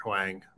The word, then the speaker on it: Hwang: Or excuse me if I'm butchering this pronunciation here. Hwang. – No, I believe that. You've got it.